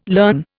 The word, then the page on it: learn: Indian